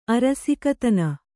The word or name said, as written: ಅರಸಿಕತನ